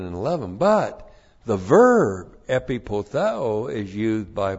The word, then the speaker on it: epipotheo: E-P-I-P-O-T-H-E long O. Epipotheo.